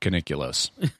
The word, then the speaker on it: Caniculus: So his name was Stavos Caniculus. – Caniculus. But some actors pronounce it Caniculus. – It's like nuclear.